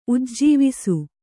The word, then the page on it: ಉಜ್ಜವಿಸು: ujjavisu